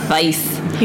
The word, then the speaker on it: Weisz: Weisz.